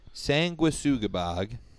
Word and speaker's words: Sanguisugabogg: And it is pronounced Sanguisugabogg.